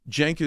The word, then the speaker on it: Cenk: Cenk is tough spelling. It's C-E-N-K, right? It's a Turkish name. The C is pronounced like a J.